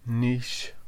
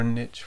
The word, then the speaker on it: niche: Or niche, for those who prefer to pronounce it that way. But I think we do. – I should tickle you. – Why? – Because you said niche. – I like that word. It's kind of fun. But anyway. – Rhymes with quiche.